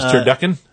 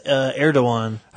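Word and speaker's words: Erdoğan: Is Turducken? – No, is Erdogan. – I just think Turducken. And it's spelled like A-R-G...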